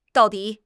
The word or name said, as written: dao4 di3